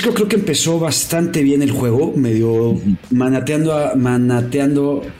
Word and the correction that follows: maniatando: Sí, ¿cómo se dice? Maniatando. – Porque el otro es cuando estás con un manatí maniatándotelo, ¿no? Pero esto es maniatando.